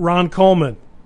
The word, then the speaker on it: Ron Coleman: Twitter, at Ron Coleman. Like it sounds, spelled like it sounds, okay? With an E after the L.